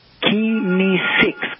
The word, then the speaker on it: kinesics: It's pronounced kinesics.